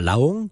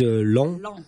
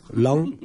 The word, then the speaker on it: Laon: Alors, on va, moi je dirais plutôt à Laon, mais là-haut, ils disent Laon. Laon, voilà. Mais ça s'écrit L-A-O-N, et c'est la capitale du département de l'Aisne, ou de l'Aisne.